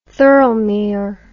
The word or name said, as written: Thirlmere